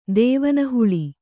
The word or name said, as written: ದೇವನಹುಳಿ